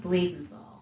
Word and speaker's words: Blumenthal: it's pronounced Blumenthal. – Blumenthal. Okay. All right. Thank you. – Just the way it's spelled.